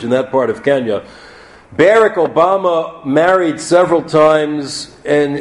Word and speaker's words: Barack: His father, Barack Hussein Obama, who became senior, and that's how he pronounced his first name, Barack, with the British accent that he had, was a Kenyan from the Luo tribe, an economist who became a government bureaucrat.